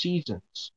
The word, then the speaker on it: SZNZ: Just say seasons, bro. It's literally seasons. That's it. – But it's SZ. It's SZNZ, bro. – Yeah, it's pronounced seasons.